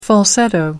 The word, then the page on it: falsetto: American English